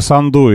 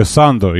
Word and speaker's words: Санду: Ну, то есть вы хотите сказать, что, смотрите, мы должны, если Санду или Санду, я так и не понял, потому что слушатель сказал, что на последний слог ударение правильно, а сам называет ее на первый.